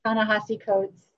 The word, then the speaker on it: Ta-Nehisi Coates: I'm reading a book by Ta-Nehisi Coates. I don't know if I said his name correctly. – No, that's it. Yeah, it is.